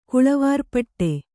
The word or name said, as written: ಕುಳವಾರ್ಪಟ್ಟೆ